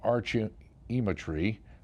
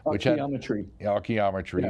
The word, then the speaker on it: Archaeometry: The Journal of Archaeometry. I'm saying that wrong. – Archaeometry. – Okay, there you go.